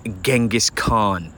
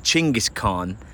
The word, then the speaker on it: Chinggis Khan: So number one, let's talk Genghis Khan, which the true pronunciation of his name is Chinggis Khan.